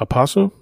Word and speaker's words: Rapace: Also es sind sehr, sehr viele Szenen dabei, wo man ich denkt, fand, was ich fand den Charakter von Noomi, ich glaube, Rapace. – Ja, so wird das im Schwedischen ausgesprochen.